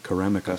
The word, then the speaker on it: Karamika: I never know if I'm saying it right, Karamika. Is it right with my accent? – Yeah, correct. Perfect. – Karamika.